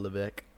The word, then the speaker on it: Levesque: Paul Levesque. – Yeah, Paul Levesque. I've never heard that his name was Levesque. – I don't know. Like, it looks so weird. It doesn't look like you pronounce it Levesque.